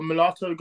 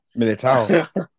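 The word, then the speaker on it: Militão: That Milito or Milato goal, however you spell it. – Militao.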